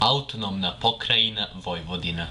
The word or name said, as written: Autonomna Pokrajina Vojvodina